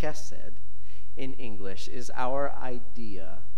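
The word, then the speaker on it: chesed (חסד): But this word that they are translating is a Hebrew word, chesed. You know, kind of like spit from your throat a little bit.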